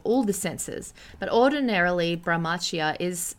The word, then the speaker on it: Brahmacharya: It's actually pronounced Brahmacharya, and it's a Sanskrit word meaning chastity.